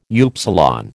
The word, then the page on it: upsilon: American English